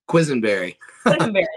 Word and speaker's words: Quesenberry: Quesenberry.